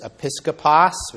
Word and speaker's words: episkopos: The word overseer in this passage is episkopos, which you can hear it sounds like the word episcopal.